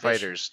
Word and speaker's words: FighterZ: Like this week, Thursday, Dragon Ball FighterZ, or Fighters, however you pronounce that game, is coming in to do an exhibition. – Fighters, just Fighters. – Is it just Fighters?